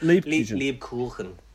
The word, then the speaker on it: Lebkuchen: Was that the correct pronunciation? – I think it's Lebkuchen.